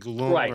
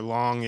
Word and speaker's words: Leung: It's L-E-U-N-G. It's like Leung or Long.